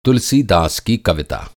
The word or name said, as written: कविता